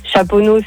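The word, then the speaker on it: Chaponnay: Chaponneau, c'est ST, tu le prononces pas.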